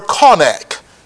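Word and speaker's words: chanak: That word train in the Hebrew, it is the word konak. It is spelled C-H-A-N-A-K. C-H-A-N-A-K.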